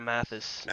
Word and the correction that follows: Matthews: Matthews. – Matthews. I knew I was going to say it wrong.